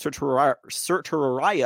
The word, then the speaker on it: certiorari: Which is pronounced certiorari also. I had to look at pronunciations on that one.